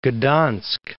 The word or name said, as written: Gdansk